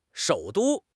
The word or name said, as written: shou3 du1